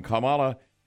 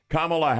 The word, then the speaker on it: Kamala: but it really got rough when Kamala Kamala Harris.